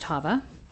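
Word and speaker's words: Chava: My name is Hava Lee, and that's C-H-A-V-A, and it really is pronounced Hava.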